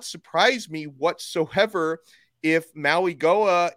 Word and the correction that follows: Mauigoa: is, and actually, let me try to pronounce his name correctly. I've been corrected on this twice. Mauingoa, I think is how you say his name oh, correctly.